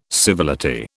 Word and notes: civility: British English

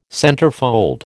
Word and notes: centerfold: American English

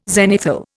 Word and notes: zenithal: Indian

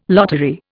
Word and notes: lottery: Indian

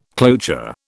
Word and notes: cloture: British English

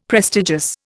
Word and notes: prestigious: Indian

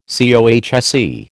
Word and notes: COHSE: American English

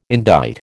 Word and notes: indite: American English

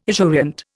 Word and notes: esurient: Indian

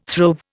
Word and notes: throve: Indian

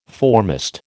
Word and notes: foremast: American English

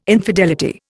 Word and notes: infidelity: Indian

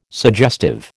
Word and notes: suggestive: American English

